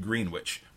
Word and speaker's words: Greenwich: But Bruce Sutphin told us about a place just to the east of Saratoga Springs, a village called Greenwich, where they pronounce it as spelled, and also Puzzle Sister.